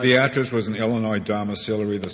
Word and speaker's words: Beatrice: It's named after a little town in Nebraska, and I think it's pronounced Beatrice. – Beatrice. Mr. Chief Justice, I will pronounce it Beatrice.